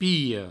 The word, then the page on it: pilha: piho